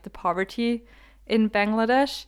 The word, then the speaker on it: Bangladesh: And it is, drumroll please, Bangladesh, that's the English pronunciation. In German, it's Bangladesh.